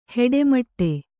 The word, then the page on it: ಹಿಟ್ಟುಮಟ್ಟಿ: hiṭṭu maṭṭi